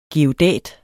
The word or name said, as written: geodæt